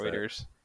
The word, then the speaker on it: Reuters: So the headline here from was it Reuters or Reuters? I forget how you pronounce it. – Reuters.